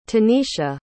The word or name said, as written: tenisha